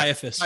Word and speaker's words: Caiaphas: Is it hard C? – Yeah. Caiaphas.